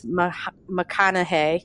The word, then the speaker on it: McConnaughey: Liz McConaughey. Did I say it right? McConaughey.